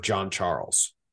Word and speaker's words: Jean Charles: It's Shamar John Charles. – But it's spelled Jean Charles. – But it's spelled J-E-A-N, like Billy Jean.